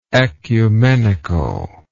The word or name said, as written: oecumenically